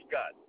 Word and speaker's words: Scott: Scott. S. Raymond Scott. S-C-O-T-T.